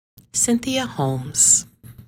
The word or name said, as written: Cynthia Holmes